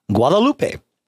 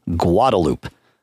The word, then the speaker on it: Guadalupe: Guadalupe is another street. What do they call it in Austin? Guadalupe. Are they correct in Austin? No. Is that what everybody calls it because that's what everybody calls it? Yes. But it is an incorrect pronunciation.